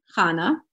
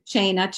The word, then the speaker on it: Chana: So many people can't say my name, Chana. They call me Chana, China, Kahana, all kinds of names. And that's not my name.